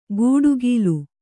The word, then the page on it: ಗೂಡುಗೀಲು: kannada; gūḍugīlu